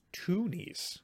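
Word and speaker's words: Twonies: Toonies is spelled T-W-O-N-I-E. – Yeah, two. – I know, but Toonies, I would think like tune, like a cartoon.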